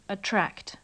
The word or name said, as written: attract